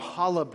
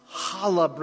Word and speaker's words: challah: Some of our ladies got together, my wife and some others, and they baked some challah bread. Now, challah bread, okay? That's how you say it. Challah. I can't get the name, but it's pretty close.